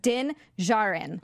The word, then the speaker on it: Djarin: Sorry, I was just going to say it's pronounced Din Jarin. Oh, Jarin. Jarin.